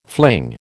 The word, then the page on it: fling: American English